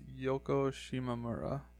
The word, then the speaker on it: Yoko Shimomura: All the music is written by Yoko Shimomura. I have no idea if I pronounced her name right, though. – I would say it's probably pronounced Yoko Shimomura. – Yoko Shimomura? – Yeah, if that's...